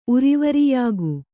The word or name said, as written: ಉರಿವರಿಯಾಗು